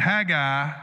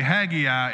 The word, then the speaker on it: Haggai: So I'm going to go with Haggai as my baseline. If I slip up and say Haggai, it's fine. I found out at 2 a.m. you can pronounce it either way.